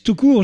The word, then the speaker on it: Toucourt: Alors, un talent d'ici avec notamment Alex Toucourt.